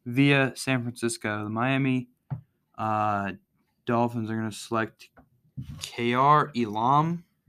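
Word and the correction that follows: Kaiir Elam: I just butchered his name. Cornerback out of Florida. – It's pronounced Kair Elam.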